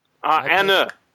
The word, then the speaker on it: Anna: So I really appreciate having you on, Anne. Anna, damn it.